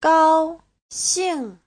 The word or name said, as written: gao1xing4